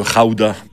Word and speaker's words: gouda: Le pays d'où vient le fromage, le gouda ou le raouda, parce qu'ils prononcent les G comme des R dans ce pays, c'est les Pays-Bas.